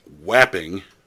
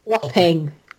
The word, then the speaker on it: Wapping: And it's the photograph of, and I'm going to do this as an American, Wapping. Go ahead, correct me. – Wapping.